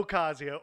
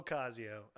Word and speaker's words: Ocasio: Alexandria Arcasio Cortez. All right. There you go. – Ocasio. There's no R. – Ocasio. Or Casio? – There's no R. – Ocasio.